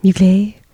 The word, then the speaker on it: Mugler: I never really dove into Mugler scents. Am I saying that wrong? – No. – Mugler. Anyway.